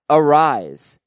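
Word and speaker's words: Arraez: He pronounces it Arise, no S sound on the end like Luis Arias.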